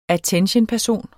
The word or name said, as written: att.-person